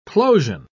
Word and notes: plosion: British English

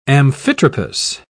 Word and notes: amphitropous: British English

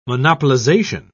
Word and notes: monopolisation: British English

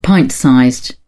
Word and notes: pint-sized: British English

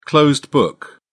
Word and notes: closed book: British English

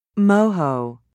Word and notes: moho: British English

